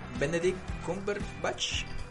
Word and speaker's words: Benedict Cumberbatch: Benedict Cumberbatch.